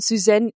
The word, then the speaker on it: Suzanne: Das waren Pauline Clance und Suzanne Im.